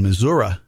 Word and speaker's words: Missouri: And Missouri, as I'm told it's pronounced, that's 6%.